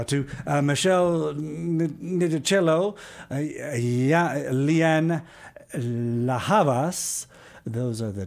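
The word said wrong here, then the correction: Ndegeocello: You don't have to say who you are just yet, but special help from one of our three special guests here to pronounce that last name for me. – Yeah, it's Michelle Ndege Ocello.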